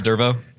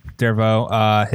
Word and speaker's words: Dervaux: It's French. It's like Renault, but Dervaux. – Okay. Dervaux.